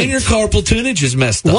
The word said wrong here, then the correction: tunnel: I said it wrong, didn't I? – Yeah, I think you did. A carpal tunnel. – Tunnel. That's it. Not tunage.